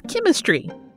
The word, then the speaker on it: chymistry: called chymistry. It sounds like chemistry, but it's spelled as follows: C-H-Y-M-I-S-T-R-Y.